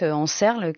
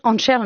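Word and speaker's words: Ančerl: J'ai mal prononcé le nom de famille, Karel Anscherl, donc en Serle, qui doit être prononcé Anscherl.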